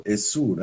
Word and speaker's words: Exu: tipo Essu, né? Como se escreve em Urubá, né?